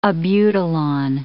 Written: abutilon

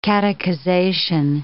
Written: catechization

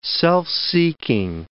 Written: self-seeking

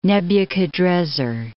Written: Nebuchadrezzar II

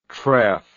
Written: tref

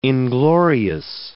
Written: inglorious

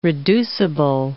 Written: reducible

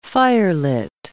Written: firelit

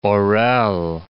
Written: Orel

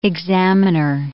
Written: examiner

